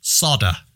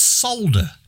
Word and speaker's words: solder: And the one that really gets me is why they can't pronounce the letter L. Sodder. Sodder, two wires together. Sodder? Sodder. It's solder.